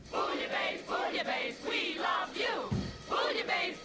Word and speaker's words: Bouillabaisse: This is Pandora's Lunchbox, and those were the DeMarco sisters and Bouillabaisse, helpfully with the pronunciator on the label, Bouillabaisse. It's French. Pronounce Bouillabaisse.